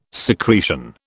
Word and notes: secretion: British English

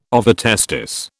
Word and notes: ovotestis: British English

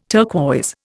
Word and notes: turquoise: Indian

